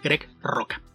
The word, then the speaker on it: Rucka: Otro caso en el que también nos dejamos llevar por la ortografía es el de Greg Roca, que se escribe Ruca, pero obviamente la pronunciación en otro idioma es distinta, entonces lo correcto es decir Greg Roca.